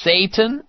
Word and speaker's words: seitan: And it's spelled S-E-I-T-A-N, but pronounced Satan Bacon.